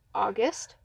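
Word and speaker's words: Augest: My name is August.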